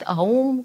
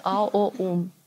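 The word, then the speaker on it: Om: Где-то говорят ОМ, где-то говорят АУМ, где-то говорят АОУМ. От чего это зависит? От того опыта, который у вас есть в прошлом. То есть пробуйте различные варианты на себе. Кто-то получит опыт определенный, произнося просто ОМ, кто-то АУМ, а кто-то АОУМ.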